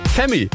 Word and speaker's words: F3MIII: Ja, der wird nämlich ganz anders geschrieben, der wird so F3MIII geschrieben. Also gut, hätte man auch auf Femi kommen können, aber ihr wisst, was ich meine.